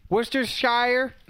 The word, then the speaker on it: Worcestershire: Worcestershire.